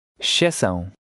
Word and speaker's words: Exceção: In number 12, the EXC will become sh, and the P is silent. Try it. – Exceção.